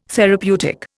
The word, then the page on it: therapeutic: Indian